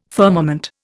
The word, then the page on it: firmament: Indian